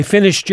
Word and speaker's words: finished: Donc, on prononce le « ed » « t » tout simplement parce qu'il serait difficile de prononcer « finished ». Donc, c'est bien « finished ».